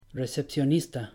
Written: recepcionista

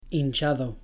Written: hinchado

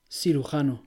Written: cirujano

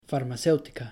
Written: farmacéutica

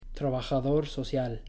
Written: trabajador social

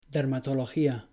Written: dermatología

